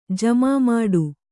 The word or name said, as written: ಜಮಾಮಾಡು